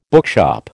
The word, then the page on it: bookshop: American English